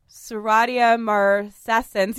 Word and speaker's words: Serratia marcescens: And this is also this is why your shower gets so like quickly moldy and mildewy and Serratia marcescens. You guys, I said it right on the first try.